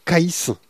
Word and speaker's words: Caïs: Bulle Critique nous permet d'avoir les avis de Caïs. Ça s'écrit comme Caïd mais avec un S, tu vois. – Caïs, quoi.